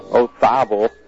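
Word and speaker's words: Olazábal: It's a Z, but it's pronounced T-H. Ozabal.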